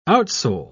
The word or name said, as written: outsole